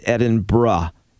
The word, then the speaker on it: Edinburgh: For example, Edinburgh is actually pronounced Edinburgh. Even though it's spelled B-U-R-G-H, and that's our fault for saying it wrong.